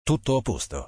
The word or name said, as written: Tutto a posto